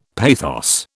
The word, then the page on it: pathos: British English